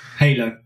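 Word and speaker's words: Hailo: And then we've also had Hilo. I think it's pronounced that way. – Halo. Hailing a taxi.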